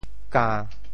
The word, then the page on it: 柑: ka~1